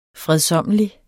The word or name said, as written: fredsommelig